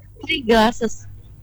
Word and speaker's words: Tiglases: Dari ini bacanya gimana? Tiglases atau bagaimana? – Tiglases. – Tiglases. – Tiga itu tiga. – Oh, tiga. Tiglases.